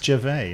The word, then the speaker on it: Gervais: It's Gervais, is it? – Yeah. A silent S like the P in swimming pool.